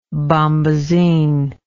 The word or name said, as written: bombasine